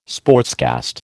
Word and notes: sportscast: American English

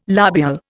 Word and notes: lobule: Indian

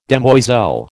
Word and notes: demoiselle: American English

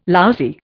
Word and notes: lousy: Indian

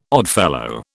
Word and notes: Oddfellow: British English

